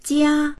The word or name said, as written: jia1